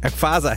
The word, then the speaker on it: Emphase: Ich kenne dieses Wort nicht. Emphase heißt das. Kommt das von Empathie, Herr Pähler? – Ich glaube ja, deswegen spricht man es auch anders aus.